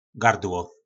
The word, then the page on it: gardlo: po nasimu